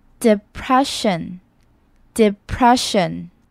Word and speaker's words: depression: 第一个 e 是短音的 e 的音，而第二个 e 呢是 e 的音。 depression，depression。